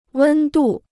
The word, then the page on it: 温度: wēn dù